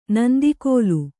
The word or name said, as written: ನಂದಿಕೋಲು